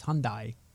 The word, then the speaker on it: Hyundai: So we say Hyundai in North America, but it's if you go to Korea, it's Hyundai. – Oh. – But in North America, we say Hyundai like Sunday.